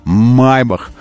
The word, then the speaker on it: Maybach: Kia папа называет Kill, Владимир Владимирович, и Maybach. Майбах.